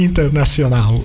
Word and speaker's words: Internacional: Vabbè, in portoghese sarebbe internazionale. – Sì, sì. – Come ha detto Michele, scusa? – Sì, internazionale. – Promosso. – Perché c'è la U finale, no? Sì, nel portoghese, appunto, c'è questo.